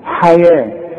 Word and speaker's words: حياة: حياة هي مكتوبة بالواو، تكتب في المصحف بالواو. لما اجي اقول لك اكتب الحياة هتكتبها ي وألف الحياة، انما كتابة المصحف خلاف الكتابة العادية، دي مكتوبة بالواو لكن انطقها بالألف، ولذلك رسم لك ألف فوق الواو.